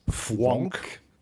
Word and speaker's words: Fvonk: Und die Sue Imhoff macht gemeinsam mit der Susanne Höllier eine Sendereihe, die heißt Fwonk. – Fwonk dich frei. – Und Fwonk schreibt man F-V-O-N-K.